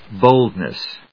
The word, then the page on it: boldness: ˈboldnʌs; ˈbəʊldnʌs